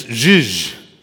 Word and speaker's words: zhuzh: Anyone want to try pronouncing this? Zuz? Or Zuz? It's zhuzh. Zhuzh.